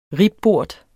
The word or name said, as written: ribbort